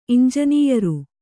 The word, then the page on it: ಇಂಜನೀಯರು: iñjanīyaru